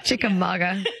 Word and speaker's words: Chickamauga: and also in Chickamauga, Georgia. Did I say that right? – Chickamauga. – Chickamauga. – It's a Cherokee word.